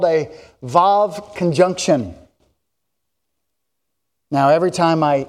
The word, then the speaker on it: Vav: I know it's spelt Waw, but it's actually pronounced Vav.